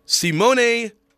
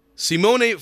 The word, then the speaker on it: Simone: where the Utah Jazz signed Italian forward Simone Fontecchio, the player who, in my head, I keep pronouncing Simon Fontecchio. I can never get the mispronunciation out of my head. But Simone Fontecchio, you may have first heard his name.